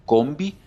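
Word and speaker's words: COMV: Eh, nosotros nos llamamos Centro Odontológico Molina Vizcarra, nos van a encontrar en redes sociales como Combi, que se escribe C-O-M-V y Latina combi.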